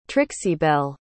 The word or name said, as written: trixibelle